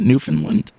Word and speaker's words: Newfoundland: We should thank Elling Lien out of, was it Newfoundland? Is that how you pronounce it? Newfoundland? – Yep.